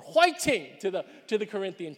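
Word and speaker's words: whiting (화이팅): Now, in Korean, it's pronounced whiting, all right? But it really is the same word, right? Fighting, whiting. But it's the idea of cheering somebody on.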